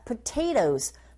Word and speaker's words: potatoes: Origin, 앞부분에 광세를 주면 되고 potatoes, potato가 아니라 potatoes, potato, 뒤에 나오는 t 사운드가 강모음과 양모음 사이에 자리 잡고 있어서 flap 현상에 의해서 t 사운드는 r 소리에 가깝게 발음이 들리고 그렇게 발음하시면 됩니다.